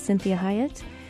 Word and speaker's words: Cinthia Hiett: You are listening to Conversations with Cinthia, and I am your host, Cinthia Hiett.